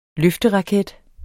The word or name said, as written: løfteraket